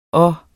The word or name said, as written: og